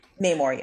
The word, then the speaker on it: Namor: Is it Namor? Is that how you say his name? – I have no idea. – Namor, yes. – Namor? Okay.